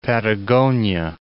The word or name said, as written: Patagonia